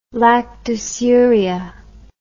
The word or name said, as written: lactosuria